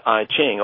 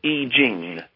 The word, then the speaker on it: I Ching: And most of the listeners to your program will have heard about the Chinese Book of Change, which they mostly would pronounce I Ching, although that's not the way the Chinese pronounce it. – I would pronounce it that way too, yeah. – Yeah, well, the Chinese say I Ching.